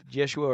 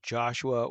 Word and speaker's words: Jeshua: Yeshua or Joshua, or in English / in the Greek, we might say Jesus.